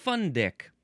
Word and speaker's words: fundic: Next is fundic. Just gonna let that sit there for a second. That seems like how it is pronounced. Fundic, spelled F-U-N-D-I-C.